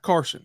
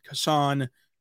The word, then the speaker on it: Cason: You cannot call him Carson, but you can call him Cason, Cason, anything else. So we're going to stick with Cason Wallace because that's what's on the official pronunciation guide.